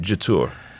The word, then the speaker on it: Jetour: Speaking to the guys at the Elite Cars, who are the distributor for this, they pronounce it Jetour. – Ah. Okay. Yep. – So same as you, but without the French.